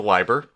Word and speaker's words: Leiber: You might remember last time I couldn't remember how it's pronounced. Thank you, Wikipedia, and your IPA notations, which I do have to Google, because sometimes they don't tell you how it's pronounced. They just give it to you, and you have to kind of like look at each symbol and figure it out. It is a Leiber.